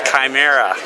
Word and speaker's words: chimera: That happened to me until somebody told me it was Chimera. You know, that's the way it's pronounced.